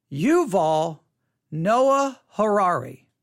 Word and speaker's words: Yuval Noah Harari: Yuval Noah Harari. Yuval Noah Harari. Yuval Noah Harari. Yuval is spelled Y-U-V-A-L. Yuval Noah, N-O-A-H, Harari, H-A-R-A-R-I.